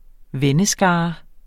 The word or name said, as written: venneskare